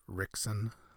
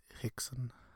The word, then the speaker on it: Rickson: It's interesting, right, in Brazil, how the R in the beginning of names or words has a H sound. It's like almost like a very breathy H. So instead of Rickson, it's Hickson, as I understand it.